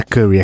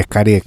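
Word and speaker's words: kare (カレー): Não é curry, é kare, que é a pronúncia japonesa do bagulho.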